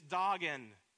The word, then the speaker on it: Dagon: Sometimes we pronounce it Dagon, but it's Dagon. The word Dagon has at its root dog, D-A-G, which again in Hebrew means what?